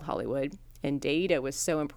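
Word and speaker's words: Daeida: The actual name of Hollywood came from Daida. It's D-A-E-I-D-A. I specifically looked up the pronunciation.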